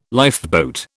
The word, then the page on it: lifeboat: British English